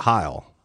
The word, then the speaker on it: Heil: Um, and I, and the old one was a Heil. Is that how you pronounce it? Yeah.